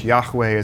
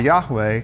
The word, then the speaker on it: Yahweh: Yahweh is how we think it's pronounced.